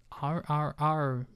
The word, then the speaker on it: RRR: it's hard because like I don't see anyone calling it triple R. Like I feel like I'm not allowed to call it triple R. I have to say R R R, and it's the kind of syllable you need to like let finish Yeah, you before got you repeat it.